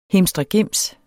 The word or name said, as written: HIMSTREGIMSEN